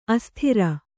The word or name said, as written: ಅಸ್ತ್ರಿ